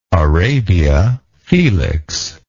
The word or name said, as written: Arabia Felix